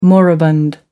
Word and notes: moribund: American English

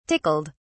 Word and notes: tickled: British English

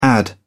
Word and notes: add-in: British English

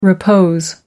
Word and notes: repose: American English

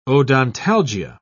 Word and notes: odontalgia: British English

